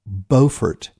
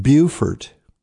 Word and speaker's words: Beaufort: On the 22nd of April, I will be speaking in Beaufort, not Beaufort. That's South Carolina, Beaufort, North Carolina, spelled the same, pronounced very differently.